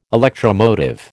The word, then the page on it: electromotive: American English